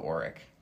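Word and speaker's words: Oriq: how do you say that? Orc? – We're going to go Auric. – Auric? Nice.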